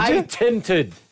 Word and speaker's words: patented: Why do you just get you don't say it correctly. – Say it then. – It's painted it. – Is it? – How'd you say it? – Is it? – What? – How'd you say it? – Again. – Painted. – What? – No! – You painted something, did you? – Patented.